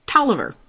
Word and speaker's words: Taliaferro: By the way, the pronunciation and actual spelling of the name Tolliver do not match. The name is actually spelled T-A-L-I-A-F-E-R-R-O, Tolliver. Just so you know.